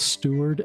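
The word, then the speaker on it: Steward: My guests on today's podcast are my friends Travis Stewart and Margaret Stewart.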